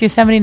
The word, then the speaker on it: Yosemite: Is that what you guys Yosemite National Park. – Yes. I'm Canadian. I don't know how to pronounce anything. – That's okay. It's Yosemite National Park and the mountain's called El Capitan.